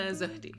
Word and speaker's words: Zohdy: Netta Zohdy, yeah. Netta, but nobody Zohdy ever gets it. – So. Zohdy? – Yep, that's right, yeah.